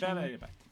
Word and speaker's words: La Belle et la Bête: La Belle et la Bête.